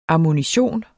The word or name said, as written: ammunition